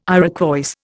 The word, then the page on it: Iroquois: Indian